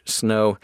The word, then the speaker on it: Snoh: Snow Allegra is the singer, and her first name is spelled S-N-O-H, Snow. And Allegra has two A's at the beginning, A-A-L-E-G-R-A, Snow Allegra.